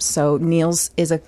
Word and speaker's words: Nils: N-E-A-L-S? – N-I-L-S. – Oh, Niels. – Niels, but it's pronounced Niels. – Yeah, it's not. – Like Niels Lofgren.